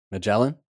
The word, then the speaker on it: Magellan: If there is a goal or an ambition that you have in your life, the bigger it is, I can't pronounce his name in English, Michelin. – Magellan? – Michelin. That sounds like a tire.